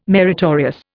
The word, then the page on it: meritorious: Indian